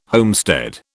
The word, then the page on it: homestead: British English